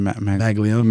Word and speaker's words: Maglione: Maglione.